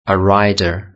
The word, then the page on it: a rider: British English